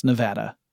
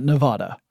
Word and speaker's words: Nevada: So I went to CES 2019 in Las Vegas, Nevada, which I have learned is pronounced Nevada. Not Nevada.